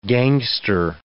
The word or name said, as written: gangster